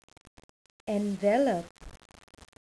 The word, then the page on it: envelope: verb